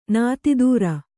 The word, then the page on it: ನೀತಿದೂರೆ: nītidūre